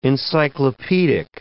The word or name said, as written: encyclopedic